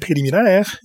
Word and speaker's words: Préliminaire: And we started with Iggy Pop from his 2009 album, Préliminaire. Yes, it's in French. That's why I pronounce it in French, because it was inspired by the writings of a French writer named Michel Houellebecq.